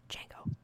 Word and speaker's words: Django: Demigio? – It's Django.